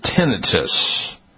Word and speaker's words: tinnitus: Well, thousands of people receiving the COVID-19 shots are reporting the persistent ringing of the ears, also called tinnitus. Some people pronounce it tinnitus.